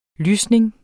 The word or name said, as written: lysning